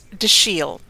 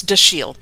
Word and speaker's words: Dashiell: So think of it in the French way, and the C-H is pronounced like Chicago, or the S-H is pronounced like Chicago. But it's Dashiell.